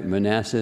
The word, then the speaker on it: Manasseh: Manasseh is the right pronunciation, was the firstborn.